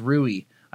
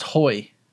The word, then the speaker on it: Rui: I always thought his name was Rui. I found out it is, that's how it's spelled, but since he's Portuguese, it's pronounced Hoy.